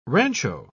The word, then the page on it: rancho: British English